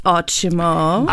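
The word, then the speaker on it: Ótimo: Yeah, so it's actually pronounced Otimo.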